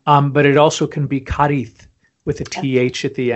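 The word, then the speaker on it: Cádiz: that I've been mispronouncing, I thought it was pronounced Cadiz, but it's Cadiz.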